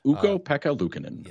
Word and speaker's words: Ukko-Pekka Luukkonen: Ukko-Pekka Luukkonen.